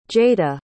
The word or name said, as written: jaeda